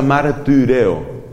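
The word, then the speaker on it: martireo (μαρτυρέω): La Y se pronuncia como la I francesa, que es una combinación entre la I y la U. Por eso se pronuncia martireo, martireo.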